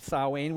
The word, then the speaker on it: Samhain: This annual change of season and lifestyle was marked by a festival called Samhain, pronounced Samhain, which means end of summer.